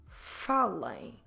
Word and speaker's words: falem: So, to say speak, talking to more than one person, talking to a group, for instance, we'll have first to stress the second to last syllable, in this case, fa, and then when you do your ending, don't close your lips in the end. It will sound like falem.